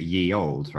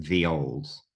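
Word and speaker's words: ye olde: But people pronounce it ye old, right? – Yeah. – Okay, so the thing is, it's still pronounced the old. The thing is with the Y, it's we used to use a character called the thorn from the Norse alphabet.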